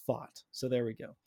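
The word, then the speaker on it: Pfaadt: I just need to let everyone know that it's pronounced Fought.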